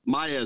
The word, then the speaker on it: Maja: And, you know, it's actually Maya, it's pronounced with like a Y, that J. It's, you know, that a lot of people get confused with looking at the spelling.